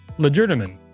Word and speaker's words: legerdemain: It is pronounced legerdemain. All right. I was saying it right.